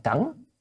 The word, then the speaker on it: cẩn: Cận, chữ này hôm nay chữ cận phải đọc là cẩn,